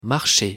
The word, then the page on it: marcher: French